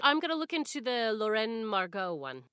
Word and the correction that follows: La Reine Margot: Lorraine Margot. How do I say it? – La Reine Margot. It's really good movie. – La Reine Margot.